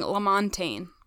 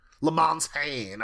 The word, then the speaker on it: LaMontagne: So the creator in the commentary was saying LaMontagne. – LaMontagne, okay. That makes sense. It's like champagne.